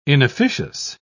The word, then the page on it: inofficious: British English